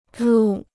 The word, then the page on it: 入: rù